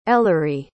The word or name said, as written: ellery